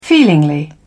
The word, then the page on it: feelingly: British English